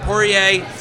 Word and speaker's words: Poirier: Poirier.